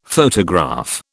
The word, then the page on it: photograph: British English